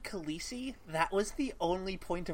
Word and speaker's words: Khaleesi: And I heard an interview with him on a podcast where he was like, yeah, Khaleesi is not pronounced like that. in the actual language that I made, E and I are not pronounced the same. You would say Khaleesi.